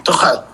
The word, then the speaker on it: Tuchel: se pronuncia Tuchel, no sé exactamente, Marcelo es el que me atreve con, ese tipo de cosas. No, Marlon Reimers, Tomás Tuchel. Ah, perdón, Tuchel.